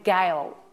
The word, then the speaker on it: Gail: When I say my name, Gail, it sounds like barrel, kind of just, it's not sweet.